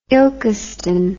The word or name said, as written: ilkeston